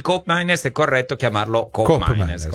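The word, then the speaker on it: Koopmeiners: Qui parliamo fiammingo, che è l'olandese, per la cronaca colgo l'occasione per dire che di Cop Miners, la doppia O di Cop Miners invece si legge U.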